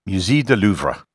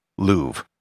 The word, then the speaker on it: Louvre: In French, the name would be pronounced Musée du Louvre. However, that is not how I'm going to pronounce it for the rest of this episode. I'll be using the most standard English pronunciation of the museum, Louvre. The reason for this is that this podcast is in English, and Louvre isn't how it's commonly pronounced in English.